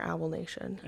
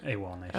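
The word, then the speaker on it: AWOLNATION: She toured with Third Eye Blind, Dashboard Confessional, and is it AWOL Nation or Owl Nation? – AWOL Nation.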